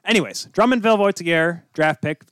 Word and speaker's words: Voltigeurs: He is an elite level player who was drafted by the QMJHL's Drummondville Voltigeurs. – Voltigeurs. – Did I get it right? – I'm pretty sure.